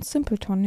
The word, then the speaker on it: Simpleton: Simpleton, ja.